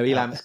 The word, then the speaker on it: Elam: So Elam, are you going to pronounce it Elam or something? – No, Elam's good.